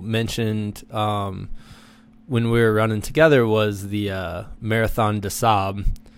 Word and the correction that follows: Marathon des Sables: which I think I'm saying that right. – Yeah. – The Marathon des Sables.